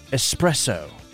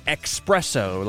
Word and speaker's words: espresso: But espresso. A lot of people add an X and say espresso, like a really fast coffee is an espresso. But there's no X in it.